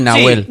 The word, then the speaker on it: Nahuel: También fue castigado Nahuel, ¿así pronuncia? – Sí, Nahuel.